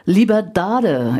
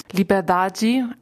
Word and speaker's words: Liberdade: Liberdade ist der Titel deines ersten Romans. – Liberdade, aber quasi im Grunde hast du es nach portugiesischem Portugiesisch richtig ausgesprochen.